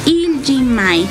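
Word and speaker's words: Iljimae: Así se pronuncia, Il Jimae.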